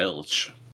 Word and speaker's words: Ilitch: Yeah, Ilitch.